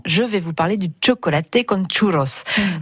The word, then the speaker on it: chocolate con churros: Le, je le, enfin, je le prononce comme je peux, hein, mais on dirait non, donc ça, se prononce vraiment chocolaté con churros, non chocolate ou chocolat.